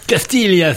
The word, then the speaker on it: Castiglia: Castiglia, s'il te plaît. – Castiglia, ah oui, c'est vrai, parce qu'on ne prononce pas le G, mais c'est vrai.